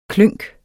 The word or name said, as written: klynk